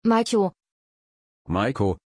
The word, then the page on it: Maiko: German